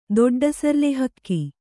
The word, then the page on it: ದೊಡ್ಡಸರ್ಲೆಹಕ್ಕಿ: doḍḍa sarle hakki